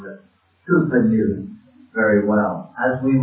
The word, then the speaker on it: Cowper: Spelled Cowper, but I'm reliably told to pronounce Cooper. So I'm going to call him Cooper throughout.